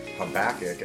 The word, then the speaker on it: Habakkuk: Habakkuk is how I pronounce it.